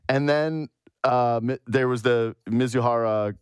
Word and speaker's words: Mizuhara: And then it was Mizuhara, is how you say his name? – Ipe Mizuhara. – Mizuhara, okay.